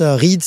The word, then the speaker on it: Ried: Alors, le paysage qu'on appelle le Ride en centre Alsace, Ride, ça s'écrit R-I-E-D, ça veut dire roseau.